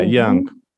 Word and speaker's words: Jung: I don't know if you pronounce it Jung. – Yeah, Jung.